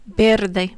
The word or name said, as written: verde